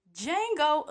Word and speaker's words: Django: Django Unchained. – The D is silent here, baby.